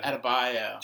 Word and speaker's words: Adebayo: And then at the end of the episode, you have Adebayo, or is it Adebayo? – Adebayo. – Just like Bam. – Yeah, yeah. – It's pronounced the same.